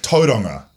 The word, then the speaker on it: Tauranga: The first place I tripped up, like, I always struggle with tauranga.